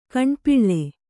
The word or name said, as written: ಗಂಡುಪಿಳ್ಳೆ